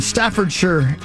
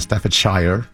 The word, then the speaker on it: Staffordshire: Rather than Staffordshire. – Staffordshire? – No, Staffordshire. – Staffordshire? – He said it properly. – He said, you did a good job. – Thank you. – Instead of saying it like most Americans.